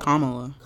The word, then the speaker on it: Kamala: Kamala. – Kamala? – Yeah. – Kamala. I've been saying Kamala. – Emphasis on the ka, the first Kamala.